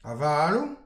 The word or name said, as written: avalou